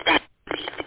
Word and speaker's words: Bad Axe: That's how it became Bad Axe or something like that. I might be saying it wrong. – No, it's Bad Axe.